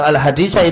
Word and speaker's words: al-haditha: Itu al-haditha itu maknanya gimana? Bacanya apa? Jawabannya bacanya mansub al-haditha.